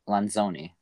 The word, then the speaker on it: Lanzoni: No, you pronounce the Z in Italian. Yeah, you do. So... Lanzoni.